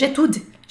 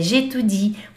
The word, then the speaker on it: j'étudie: Por muito tempo, quando eu comecei a estudar, eu falava, eu estudo francês, j'étude. Getude, Getude. Não pode. É Getudi, porque tem esse izinho aqui antes do e.